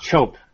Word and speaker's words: Chope: His name is Will Chope or Will Choop. I don't know how to pronounce it. Do you know how to pronounce his name? – I think it's just Chope, I think.